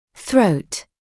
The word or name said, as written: throat